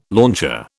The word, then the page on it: launcher: British English